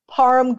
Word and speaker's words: Parham: But I'm going over 6.5 receiving yards for Donald Parham. Am I pronouncing that properly? – Yep. – I don't know. Parham, you got it.